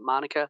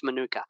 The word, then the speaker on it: Manuka: So you said Manuka at first, and everywhere around the world it's Manuka. But I think it really comes from New Zealand, Manuka honey, etc. That's the way it's pronounced. However, the urban myth here is that when the Queen came for the Jubilee, what, 77 or something like that, she pronounced it Manuka.